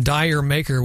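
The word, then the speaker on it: D'yer Mak'er: KX95, Jamaica, or as it appears on the album Dire Maker, which is how most Americans pronounce it, from Led Zeppelin, off the album House of the Holy.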